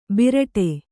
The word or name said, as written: ಭರಡಾ